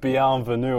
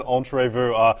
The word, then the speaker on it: bienvenue: After you butchered the pronunciation of bienvenue and said it entrevue,